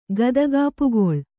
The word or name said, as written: ಗದಗಾಪುಗೊಳ್